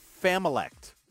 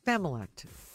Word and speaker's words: familect: Familect. – Familect. – Familect. – Familect. – You take family and dialect and you put them together.